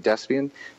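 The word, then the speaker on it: Desbiens: So Cody, C-O-D-Y, and Ray, my middle name, R-A-Y, last name, Despien, D as in dog, E-S, B as in boy, I-E-N-S.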